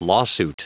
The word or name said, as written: lawsuit